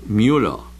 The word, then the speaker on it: Müller: Ay, mira, me gusta pronunciar estos nombres en alemán. Müller, la escritora esposa de Andrés Manuel, candidato de, bueno, bla bla bla bla bla, desde un mitin aquí en Veracruz, en Minatitlán.